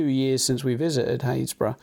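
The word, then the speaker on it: Happisburgh: It's spelled Happysburg, by the way, if anyone looks it up. – Yeah. – Happysburg. But it's actually Haysborough.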